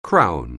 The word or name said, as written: Crown